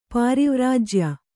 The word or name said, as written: ಪ್ರವ್ರಾಜ್ಯ